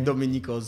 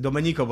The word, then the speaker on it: Domenico: Domenico, z, Domenico, bo to jest jeszcze w ogóle taka, taka włoska wymowa tego imienia e, z tego serialu.